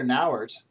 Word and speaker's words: Nauert: And one of those things is that Heather Nauert, am I saying that right? – I believe that's how you pronounce it. – Yeah, Heather Nauert is now been named, is going to be named to our UN ambassador.